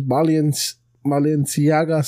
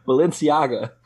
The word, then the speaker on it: Balenciaga: But, Kanye West raised $1 million for DMX's family from his Balenciaga sales. – How do you say it so Mexican? Balenciaga.